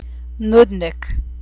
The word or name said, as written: noodnik